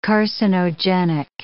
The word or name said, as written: carcinogenic